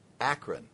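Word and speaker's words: akron (ἄκρον): The word end is akron, spelled and pronounced like the city 5 miles away.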